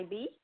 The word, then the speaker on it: Tybee: She's the author of Seeking Through the Lies, Unmasking the Myths Women Believe, A Betsy and the Mysteries at Tybee. Am I saying that right? – Tybee Island? – Tybee.